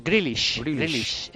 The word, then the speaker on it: Grealish: Grealish.